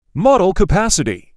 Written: Model capacity

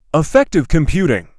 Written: Affective computing

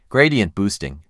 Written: Gradient boosting

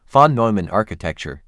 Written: Von Neumann architecture

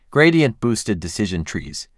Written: Gradient boosted decision trees

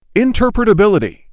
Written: interpretability

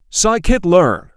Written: Scikit-learn

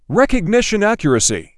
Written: Recognition accuracy

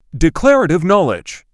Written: Declarative knowledge

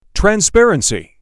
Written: Transparency

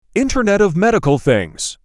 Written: Internet of Medical Things

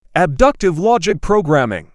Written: abductive logic programming

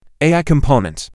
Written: AI component

